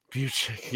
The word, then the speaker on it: Bucic: And give me a pronunciation of this guy's last name there. Do you see that comment down there in the quotations? – Bucic? – Yeah, I totally butchered there. I think I said I think I said Buke or something like there. – Bucic. – Yeah, no, I wasn't even close to that.